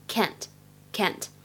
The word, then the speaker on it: can't: 首先，第一个就是这个 can't，它的话呢读作英式发音，读的是长元音的 r，can't can't。美式的发音呢读的是梅花音的 a，就会变成 can't can't。